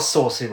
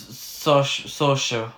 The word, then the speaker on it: Saoirse: It's not Saoirse Ronan, is it? – No, it's Saoirse.